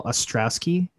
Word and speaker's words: Ostrowski: The big story, Carol Ostrowski, is that how you say his name? All right. Yeah.